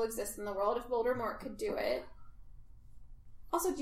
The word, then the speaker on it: Voldemort: It's supposed to be Voldemort. – T is silent. French.